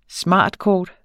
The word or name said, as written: smartkort